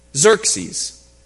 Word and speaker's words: Xerxes: Eventually, the Greeks, led by Alexander the Great, will prevail over the king of Persia, which is why we now know him by the Greek pronunciation of his name, Xerxes. That's the Greek pronunciation of his name. That's not what his people called him. The Persians called him Kasharsha.